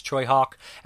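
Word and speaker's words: Tsui Hark: It's not spelled that way, but it's pronounced Choi Hawk, and this is Dangerous Encounters of the First Kind, I believe it's called that.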